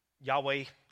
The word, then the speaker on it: Yahweh: Problem with that is that Yahweh, and I'm going to pronounce it that way. There's a lot of scholars that will say it's pronounced that way. There's some that say it's different.